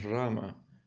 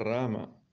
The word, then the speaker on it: Rama: Sí, Almajara, naturalmente ellos deberán cantar Rama, no Ram, porque esa es la pronunciación verdadera. Si alguien pronuncia esto como Ram, no significa que todo está estropeado, pero Rama es la pronunciación gramaticalmente correcta.